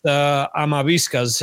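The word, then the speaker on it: Amavisca: His name in Spanish is pronounced Amaviscas, his name.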